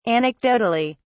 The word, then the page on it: anecdotally: British English